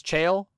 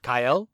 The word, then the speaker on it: Cael: I had realized that I had been mispronouncing one of the names of the Kontu, the Astral Sea equivalent that I'd been saying as Chael. In the Latin, actually, it would be Kael. So C-A-E-L in the Latin is pronounced Kael.